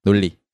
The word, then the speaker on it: 논리: And if you pronounce it naturally, it's 논리.